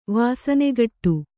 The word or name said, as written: ವಾಸನೆಗಟ್ಟು